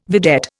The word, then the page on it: vedette: Indian